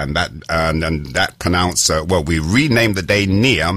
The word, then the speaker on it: Nia: N-I-A, spelled, pronounced near.